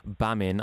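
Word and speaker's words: Gbamin: Now, another name that has come out today, Jean-Philippe Bamin. I'm presuming that is with a silent G. Have I pronounced that right? – You have, yeah.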